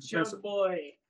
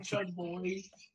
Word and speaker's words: Chug Boi: Chug Boy. B-O-I. It's commonly misconstrued. Chug Boy. My name is Chug Boy.